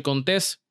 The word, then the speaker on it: Contés: Mi nombre es Miguel Contés, con acento en la E, y nos vemos la próxima.